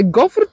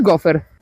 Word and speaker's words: gofr: Otóż nie gofer, tylko gofr. To jedyna i poprawna i dopuszczalna forma. A wymawia się trudno gofr, bo nazwa pochodzi z francuskiego.